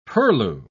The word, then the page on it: purlieu: British English